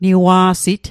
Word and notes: niwa'sit: Mi'gmaq

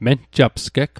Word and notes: menjapsgeg: Mi'gmaq

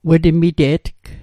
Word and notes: wetmite'tg: Mi'gmaq

